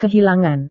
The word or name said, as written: kehilangan